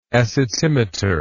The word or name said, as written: acetimetry